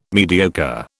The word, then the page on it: mediocre: British English